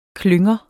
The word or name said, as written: klynger